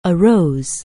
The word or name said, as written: arise